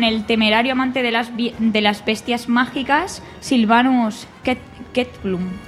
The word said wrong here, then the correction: Kettleburn: Eso ha sonado muy mal. – Es que yo creo que lo pronuncio bien. – Kettleburn. – Kettleburn.